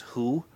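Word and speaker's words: who (הוא): Ironically, the pronoun is who.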